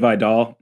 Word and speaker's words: Vidal: Caden, why don't you tell us a little bit more about this Troy offense and specifically, what is it, Vital? I hope I'm saying that right, the running back? Because dude is a monster. – Kamani Vidal. – Vidal, there we go.